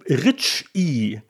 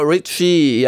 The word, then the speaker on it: Reiche: aber die Rich I sei die korrekte Aussprache. Genau, Richie,